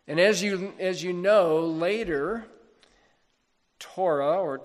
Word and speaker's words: Torah: Our English word law is translating a Hebrew word, the Hebrew word you've heard of, Torah, or Torah, actually, but Torah is the way that people normally pronounce it, which at its basic meaning is teaching.